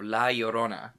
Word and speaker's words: La Llorona: Shall we then move on to The Curse of La Llorona? – Yes, let's.